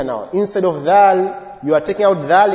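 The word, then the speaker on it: dhal (ذال): The second letter which we pronounce, or when we pronounce, the tongue comes out is dhal. Is the one we're talking about now. Dal, dhal, dhal. The tongue will also, must come out in that pronunciation.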